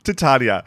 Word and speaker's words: Titania: Is it tit Titania, or did you just say it wrong? – Titania. – Okay.